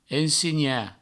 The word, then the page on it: ensenhar: ensegna; ensigna; provençal